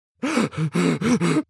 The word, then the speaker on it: knackered: Knackered is an informal word that means very tired. It's got a silent K at the beginning. The word is pronounced knackered.